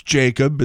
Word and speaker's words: Jakub: And then we got a new Blues player that was just involved in the trade a couple of days ago, and he spells his name J-A-K-U-B. It's Jacob. It's an unusual spelling, but that's it.